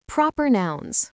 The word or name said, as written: proper nouns